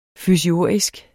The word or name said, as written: fysiurgisk